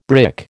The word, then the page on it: brick: American English